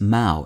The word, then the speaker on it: mow: The other is mouwe, M-O-W, which is pronounced to rhyme either with no or now.